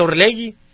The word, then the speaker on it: Orlegi: se escribe Orlegi y se pronuncia Orlegi, fue lo que nos dijeron desde que apareció, y bueno, pues así lo decimos,